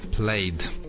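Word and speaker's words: Plaid: Плейд, если произносить это правильно по-английски.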